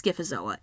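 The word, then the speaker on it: Scyphozoa: So this, I think it's Skiffozoa. It's, phonetically, it's spelled Skiffozoa, like P-H.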